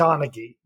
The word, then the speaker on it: Donaghy: Donaghy.